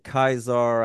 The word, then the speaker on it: Caesar: And yes, it's the only time I'm going to pronounce it Caesar. I know they don't have soft C's. It's supposed to be Caesar, but we're probably going to say Caesar from now on.